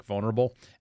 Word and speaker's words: vulnerable: One, when you repeat the same issue over and over again because you're so incompetent at what you do and you mispronounce words that are simple like vulnerable, there's an L in there, sweetheart.